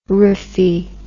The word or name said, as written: rrhaphy